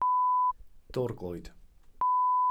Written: dorglwyd

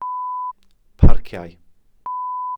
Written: parciau

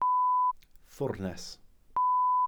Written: ffwrnes

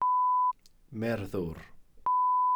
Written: merddwr

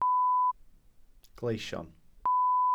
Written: gleision

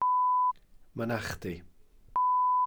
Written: mynachdy

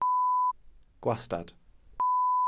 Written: gwastad

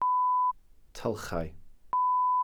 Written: tylchau

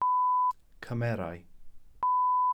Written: cymerau

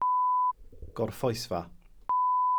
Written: gorffwysfa

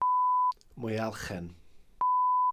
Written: mwyalchen

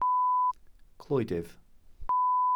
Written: clwydydd

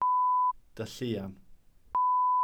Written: dylluan

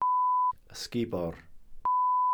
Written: ysgubor

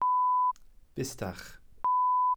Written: bustach

